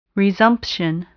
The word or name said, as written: resumption